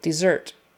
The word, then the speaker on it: dessert: Desert versus dessert. Similar sounds, but the accent is on the second syllable, dessert.